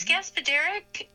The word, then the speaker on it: Gaspodarek: it would be Gaspodarek, something like that. – Yes, it's Gaspodarek. – Gaspodarek. And, mm-hmm. and I actually knew that because it said the accents on the third syllable.